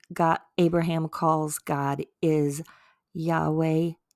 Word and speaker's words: Yahweh: I think we would, as Americans, say Yaweh Yireh, Y-A-H-W-E-H. And the A and the E is put in there so that you know how to really pronounce it. Like, true Hebrew doesn't even have the A and the E in there. And then the Yireh is Y-I-R-E-H, and it means the Lord will provide.